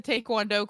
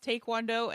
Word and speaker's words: taekwondo: three year old that like he went to the taekwondo class, which is apparently pronounced taekwondo and not taekwondo.